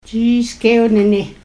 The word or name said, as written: jiis'kewnini